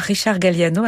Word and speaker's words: Richard Galliano: Un extrait de la petite suite de Richard Galliano, joué par Richard Galliano avec le South Czech Philharmonic, dirigé par Miran Vaupotic. Je ne sais pas si c'est comme ça qu'on prononce Richard Galliano. – Oui, c'est ça.